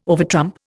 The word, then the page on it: overtrump: Indian